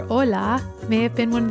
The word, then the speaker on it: hola: In Spanish, that word is spelled H-O-L-A, but the word for ocean wave is O-L-A, even though they sound the same.